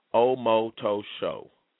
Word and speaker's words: Omotosho: It's all long O's. Omotosho.